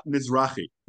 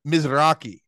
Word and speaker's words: Mizrahi: They again, Mizrahi Jews. Is, how do you say that? Mizra- Mizrahi. Mizrahi.